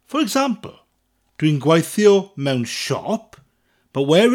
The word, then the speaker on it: siop: Spelt double F-A-T-R-I. Dwi'n gweithio mewn ffatri. Dwi'n gweithio mewn ffatri. Dwi'n gweithio mewn ffatri. – Now, shop in Welsh is an easy one because it's said exactly the same way in English and Welsh. Shop. But it's spelt differently. It's spelt S-I-O-P. Shop.